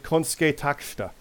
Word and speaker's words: Konosuke Takeshita: I know I'm saying it wrong. It's Konosuke Takeshita. you hear Excalibur say it, it's like Konsuke Takeshita.